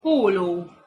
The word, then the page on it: polo: Hungarian